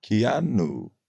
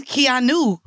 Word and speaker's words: Keanu: No, it's not. There's an A. Like, it's K-E-A-N-U. – Kianu. – It should be Keanu. – Isn't that what it is? – It's Keanu Reeves.